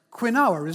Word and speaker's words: quinoa: Help me understand how that word is pronounced quinoa. It's pronounced quinoa, isn't it? It's quinoa. It's not quinoa, but somehow we've called it that.